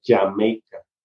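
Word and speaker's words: D'yer Mak'er: La entire Maker, creo que se llama, la, la que se escribe Dyer Maker, pero se pronuncia Jamaica.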